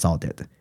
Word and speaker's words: saudade: 哦，那我就找到一个他们当地的词语，有点接近这位导演想要捕获的感觉，就是在葡萄牙语和加里西亚语中有个词汇叫做 “saudade”，哦，它的发音就是 “saudade”。那这个词的含义就是悲中有乐。哦，那其实你翻阅各大语语语文，就除了葡萄牙语，其实没有其他语言有这样一个描绘这样感觉的词汇。那这个 “saudade” 指的就是说，对于曾经。